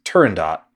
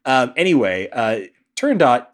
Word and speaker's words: Turandot: because it's Italian and not French. I was pronouncing it Turando in my head for a while. Turandot is based on the opera by Puccini, which I wasn't familiar with, so I Googled it before diving into the game.